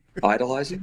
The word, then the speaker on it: idolizing: then you are also idolatizing, or you're idolatizing. How do you say that word? Idolatrizing? – Idolizing. – Idolizing. Yeah. Thank you. I added an extra syllable for no reason.